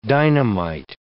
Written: dynamite

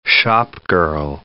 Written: shopgirl

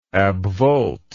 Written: abvolt

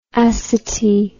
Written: acity

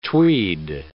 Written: tweed

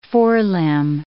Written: forelimb

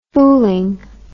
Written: fooling

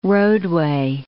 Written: roadway

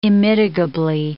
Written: immitigably

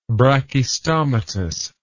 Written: brachystomatous

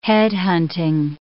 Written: head-hunting

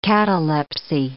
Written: catalepsy

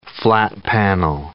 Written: flat-panel

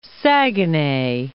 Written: Saguenay

